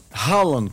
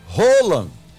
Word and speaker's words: Haaland: Haaland, como todo mundo chama, mas a Cláudia pesquisou e a pronúncia é Haaland.